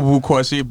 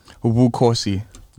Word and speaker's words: Bukosi: It's short for Bukosi, but even back home, no one can pronounce that.